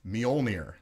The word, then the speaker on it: Mjölnir: The whole thing that we're going to be doing is who is worthy enough to wield Thor's hammer, Mjolnir.